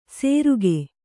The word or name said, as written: ಸೇರುಗೆ